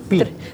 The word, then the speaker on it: Pi: Usted lo pronuncia Pi en inglés, pero la fórmula matemática siempre es Pi.